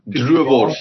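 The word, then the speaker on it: droëwors: I'm thinking it's pronounced Duwers. – Druivors, yeah. So Druivors is basically dried sausage.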